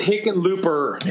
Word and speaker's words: Hickenlooper: I'm just thinking, like, having President Hickenloop, can you, like, just. – Hickenlooper. – It's Hickenlooper. – Yeah, there's even more syllables.